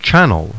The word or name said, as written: CHANNEL